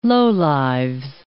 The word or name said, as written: lowlives